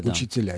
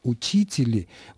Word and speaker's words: учителя: Переход ударения на окончание вместе с меной окончания «и» на «а» – это процесс, который затронул большое количество русских существительных мужского рода с нулевым окончанием. Процесс этот идет с XVIII века и не завершен до сих пор. Поэтому, когда сегодня мы слышим по радио, как кто-то говорит «учители», мы просто слышим архаичное ударение.